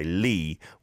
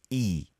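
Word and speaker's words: E (이): In English, we say Lee, whereas the surname actually is E.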